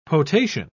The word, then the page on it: potation: British English